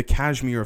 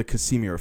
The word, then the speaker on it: Casimir: So, what we then need to look at before we move on to the real documents of what's going on here is the Kashmir effect, or the Casimir effect, depending how you you pronounce it.